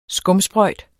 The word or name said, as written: skumsprøjt